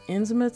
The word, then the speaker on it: Innsmouth: Except he pronounced Innsmouth so it rhymed with South, and wouldn't let anyone correct him.